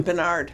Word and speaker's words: Barnard: Barnard? Tom. – Bernard. – Bernard. I put the accent on the wrong syllable.